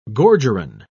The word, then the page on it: gorgerin: British English